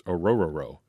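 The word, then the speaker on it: Orhorhoro: But in the official Clemson pronunciation guide, it's Orororo.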